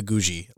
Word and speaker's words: Guji: So again, like not a sponsor or an ad or anything, but it's the Ethiopia Gucci. I don't think that's how you pronounce it. – I want to see it. – Let's get over here to. – I say Ethiopia Guji. Like it's spelled G-U-J-I, so I'd say Ethiopia Guji.